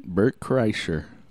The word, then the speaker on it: Kreischer: How do you say his last name? – Bert Kreischer.